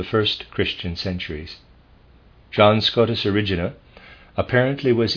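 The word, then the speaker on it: Erigena: It is spelled E-R-I-G-E-N-A. E-R-I-G-E-N-A. And I am pronouncing it one of the ways I read about, Origena.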